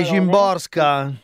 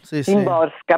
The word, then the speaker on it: Szymborska: Sì, Szymborska. – Szymborska, sì. – Perché evidentemente la S e la Z si pronunciano così.